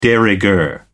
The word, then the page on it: de rigueur: American English